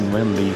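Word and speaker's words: Nguyễn Lê: O, um guitarrista que eu adoro, Nguyen Lee, Nguyen Lee, que se escreve, né, pronuncia-se Nguyen Lee,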